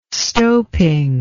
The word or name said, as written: stoping